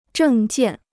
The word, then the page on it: 证件: zhèng jiàn